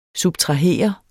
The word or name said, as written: subtrahere